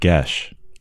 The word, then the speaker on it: geas: That word gesh. Lots of people read that wrong. How do you spell that one? – G-E-A-S. – That is a spell that no one knows how to pronounce.